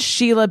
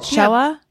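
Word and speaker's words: Shelagh: Now, I might be saying Sheila's name wrong. It's S-H-E-L-A-G-H. What do you guys think? – Ooh. – Sheila? – Yeah. Yeah. – Sure. – Sounds like an alternate spelling of Sheila.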